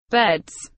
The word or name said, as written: beds